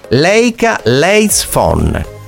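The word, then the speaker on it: Leica Leitz Phone: Leica Lights Phone. – Esatto, bravo che sei riuscito a pronunciare correttamente il nome, avevo paura io, ma l'hai detto tu, quindi non lo ripeterò, sei stato bravo.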